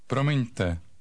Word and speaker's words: Promiňte: Promiňte. Promiňte. – Notice that the stress is on the first syllable. The trilled or flapped R in this word is common in many world languages, but not in English.